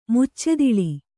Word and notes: ಮುಚ್ಚೆದಿಳಿ: Kannada

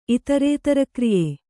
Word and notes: ಇತರೇತರಕ್ರಿಯೆ: Kannada

